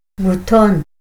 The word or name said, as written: buton